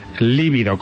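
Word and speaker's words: lívido: Por ejemplo, mira, en las autopsias, un término que se utiliza mucho, líbido, con V y con acento en la I primera.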